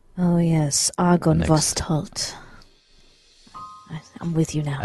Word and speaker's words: Argynvostholt: Argynvostholt.